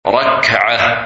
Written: Rakah

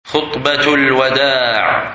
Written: Last Sermon

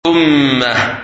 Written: Ummah

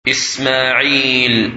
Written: Ismail

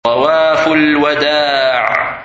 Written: Tawaf Al-Wada

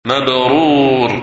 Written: Hajj Mabrur

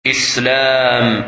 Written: Islam

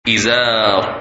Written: Izar

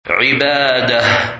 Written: Ibadah

